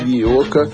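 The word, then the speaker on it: Iyeoka: E para abrir musicalmente, antes do nosso bate-papo, vamos aí ouvir um som da Nigéria, uma cantora sensacional chamada Ioka, da música Simple Fane.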